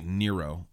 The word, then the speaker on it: Nero: So if I said Nero right there, I meant Nero. I always confuse that because I try and say it with like the Spanish pronunciation, but I think it is in fact Nero.